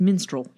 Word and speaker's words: minstrel: And menstrual shows, is that how you say it? Or am I saying it like a minstrel. show on their period? No, I mean, that is also how you pronounce menstrual, but it's minstrel, yeah.